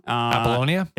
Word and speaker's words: Apollonia: The girl that plays Apollonia, I always pronounce her name wrong. – Apollonia. – Thank you.